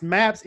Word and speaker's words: MAPPS: Based on your, is it pronounced MAPS, M-A-P-P-S? – Yeah.